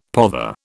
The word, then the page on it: pother: British English